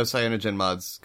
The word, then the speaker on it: CyanogenMod: Yeah, and I know CyanogenMod's got a much bigger.